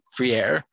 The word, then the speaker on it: Freire: Yes, it's F-R-I-E-R-E, Freire, Freire.